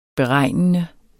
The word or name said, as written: beregnende